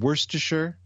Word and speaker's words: Worcestershire: I've been working on my pronunciation. Worcestershire sauce. – That's hot. I think that's right.